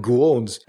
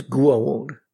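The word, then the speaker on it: Goa'uld: I thought it was goold. Like goo, not go.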